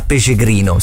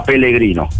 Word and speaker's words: Pellegrino: Qualcuno mi prende in giro perché io chiamo l'attaccante del Parma accostato alla Fiorentina Pecegrino. Si dice Pecegrino? – No, ecco, lo per il cognome è italiano, quindi lo pronunciano. Loro lo pronunciano con una L sola, Pellegrino.